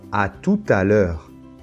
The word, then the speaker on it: à tout à l'heure: Heure, c'est ouvert. E, d'accord? Et ici, on va prononcer le T final de tout parce qu'il y a ce phénomène de la liaison.